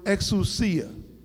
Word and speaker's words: exousia: Now the Greek word here is the word exousia. Exousia. Now that word is given to us, and that's spelled E-X-O-U-S-I-A. E-X-O-U-S-I-A.